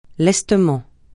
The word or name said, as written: lestement